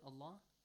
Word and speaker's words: الله: الله So how do you pronounce the word Allah? Especially the lam in this word? – Heavily. – Heavily, yes. Why? Because Hamzatul Wasl is assigned with a fatha.